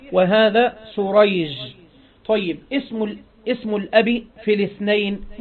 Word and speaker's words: سريج: قال: الأول بالشين المعجمة والحاء المهملة اللي هو شريح، وهو تابعي يروي عن علي رضي الله عنه. والثاني بالسين المهملة والجيم اللي هو سريج، وهو من شيوخ البخاري.